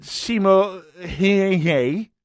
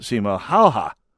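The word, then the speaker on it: Simo Häyhä: And so if you pronounced it Simo hey hey, you'd be making yourself look like a fool to a Finnish speaker. And you'd also be, if you kept pronouncing it that way after you were set straight, you would know that you were showing some disrespect to this person whose name is pronounced Sima Halha.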